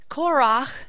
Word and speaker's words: Korach: Korach, which is the Hebrew pronunciation of it,